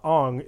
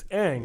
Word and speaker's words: Aang: I was like, it's not Aang, it's Aang.